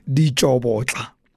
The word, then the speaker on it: Ditsobotla: If you know it as well as I do, you will know that the locals pronounce it Ditsobotla.